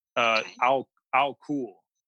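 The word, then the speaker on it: Al-kul (الكحل): The word alcohol is an Arabic word. Al-kul is how you would pronounce it.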